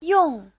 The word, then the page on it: yong: yong4